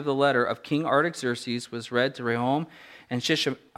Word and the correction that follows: Shimshai: oh, uh, I didn't pronounce this in advance here, Shimshai, I'm sure I'm getting that right, Shimshai, the secretary and their associates, they went immediately to the Jews in Jerusalem and compelled them by force to stop.